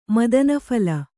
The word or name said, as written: ಮದನಫಳ